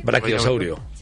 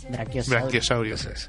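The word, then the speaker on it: Braquiosaurio: Luego el Branquiosaurios, de toda la vida, el cuello largo. Ah, ¿eh? El piecito. – Brachiosaurio. – Brachiosaurio.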